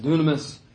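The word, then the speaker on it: dunamis (δύναμις): It's pronounced dunamis, but the U sound is actually also a Y. So it looks like the word dynamis, but that's where we get our word dynamite for power.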